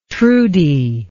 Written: trudy

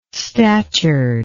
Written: statured

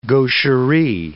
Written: gaucherie

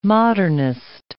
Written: modernist

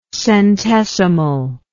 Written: centesimally

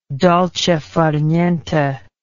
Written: dolce far niente

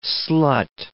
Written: slut

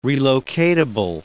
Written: relocatable

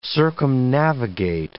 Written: circumnavigate

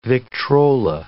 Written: Victrola

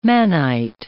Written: mannite

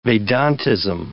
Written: Vedantism

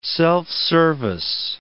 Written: self-service

